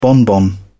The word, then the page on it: bonbon: British English